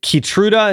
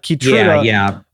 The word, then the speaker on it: Keytruda: So related to FDA approval, why is it a big deal that, is it Keytruda? Is that how you pronounce that? Keytruda was approved by the FDA for triple negative breast cancer. – Yeah. So, you know, this is a drug.